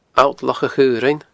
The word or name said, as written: Allt Loch a Chaorainn